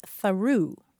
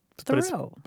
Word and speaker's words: Thoreau: She said, I taught at an Indian reservation in New Mexico, and the author named Thoreau is a town name there, but it's pronounced Tharoo. – Tharoo. – Tharoo. So you go through Tharoo. – Mm-hmm. – But you'd think it would be pronounced Thoreau. – But it's spelled like the American author.